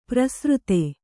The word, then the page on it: ಪ್ರಸೃತೆ: prasřte